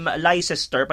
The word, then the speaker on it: Leicester: Parang naalala ko tong um, Leicester, pag nakita mo yung Leicester, uh, Leicester Street diyan sa may bandang Queen Victoria.